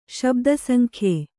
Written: ಶಬ್ದಸಂಖ್ಯೆ

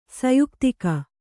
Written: ಸಯುಕ್ತಿಕ

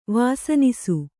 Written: ವಾಸನಿಸು

